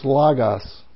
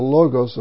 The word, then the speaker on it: Logos: If you're in the Erasmian pronunciation, it's Logos. But Koine pronunciation, it's the Logos of God.